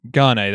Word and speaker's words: Gane: It's Lewis and Gain? Is that how you pronounce his name? Gane, that's it.